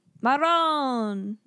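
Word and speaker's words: marone: Maron. It's my little spicy Italian meatball. – The way you say it. – Is that not how you say it? – Maron. You gotta roll the R.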